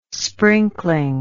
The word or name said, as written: sprinkling